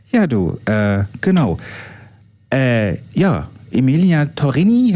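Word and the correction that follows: Emiliana Torrini: hören wir jetzt. Ich hoffe, ich habe es richtig ausgesprochen. Emiliana Torini, jetzt genau.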